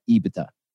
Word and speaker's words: EBITDA: He said EBITDA. – So wow, you said it right.